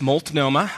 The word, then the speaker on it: Multnomah: And yes, Multnomah.